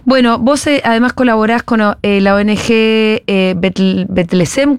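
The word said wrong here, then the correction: B'Tselem: ¿cómo la pronuncio? – Betzelem se llama. – Betzelem, perfecto, Betzelem.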